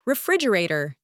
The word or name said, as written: refrigerator